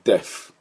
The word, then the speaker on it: deaf: で、 Deaf のスペルはですね、 D-E-A-F、ね、 D-E-A-F で Deaf、 Deaf で D の発音ですね、 T-T-T-T-T-T-T-T、ね、工事現場のドリルの音です、 T-T-T-T-T-T-T-T-T、はい、に F がついてますから、エアークサベのね、前の歯で下の唇に当たって空気が漏れる音、これを、作っていただきたいと思います。